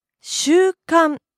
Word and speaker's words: 習慣: 習慣 Custom, habit. – Pronunciation is the same with the word for week.